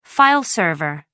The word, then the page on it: file server: American English